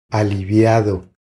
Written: Aliviado

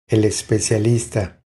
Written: El especialista

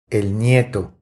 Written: El nieto

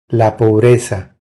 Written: La pobreza

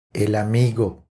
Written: El amigo

